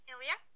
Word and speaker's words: Ilya: Is it Ilya? – Yeah, that's fine. It's like Ilya.